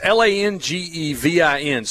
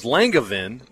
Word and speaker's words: Langevin: It's Langevin. So we all thought it was Langevin, but then Jay gave the real pronunciation of it, the French.